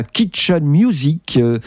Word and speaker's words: Kitchen Music: Elle crée la Kitchen Music, qui s'écrit comme ça se prononce, music et non pas à l'anglaise, qu'elle définira comme un mouvement du milieu des années 90 qui considère l'activité musicale comme peu différente de toute autre tâche quotidienne.